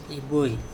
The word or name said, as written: iboi